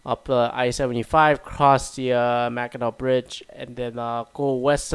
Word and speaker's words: Mackinac: Mackinac? I don't know. It's spelled Mackinac, but it's pronounced Mackinac, I feel like.